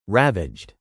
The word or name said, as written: ravaged